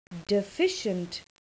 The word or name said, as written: deficient